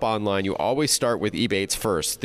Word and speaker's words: Ebates: Another app, the one that focuses more on online shopping that I love, is called Ebates. It rhymes with rebates, but no R.